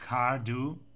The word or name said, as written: Cardhu